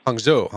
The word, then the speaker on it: Hangzhou: Hangzhou.